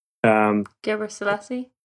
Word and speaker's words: Gebrselassie: I always pronounce his name wrong, but Haley Gebrselassie, Gebrselassie.